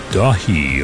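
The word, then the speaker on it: Daithi: new album from Ilion called Dahi, although it's spelled D-A-I-T-H-I. I'm pretty sure it's in Gaelic.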